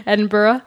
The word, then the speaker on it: Edinburgh: Am I pronouncing that right? I'm always scared I'm saying that word wrong. – Which one, Ruckley? – Edinburgh. – No, I think you said it right. – Edinburgh.